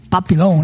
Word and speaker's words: Papillon: Papillon, né? – É isso aí.